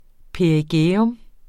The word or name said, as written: perigæum